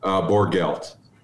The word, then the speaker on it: Borgelt: Because Borgelt. – Borgelt, just like it looks, but I always want to soften the G, I think.